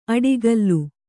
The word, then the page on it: ಅಡಿಗಲ್ಲು: aḍigallu